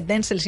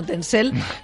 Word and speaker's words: Denzel: Y luego ya, la verdad es que lo siento mucho, Denzel, porque además no se dice Denzel, sino Denzel.